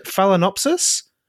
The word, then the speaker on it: Phalaenopsis: We're going to talk about moth orchids now, and please forgive me if my Latin fails me here, but is it, do you pronounce it Phalaenopsis? – Correct. Yes. Well, ooh. I mean, you can pronounce it any way you like, but yeah, that's generally the pronunciation.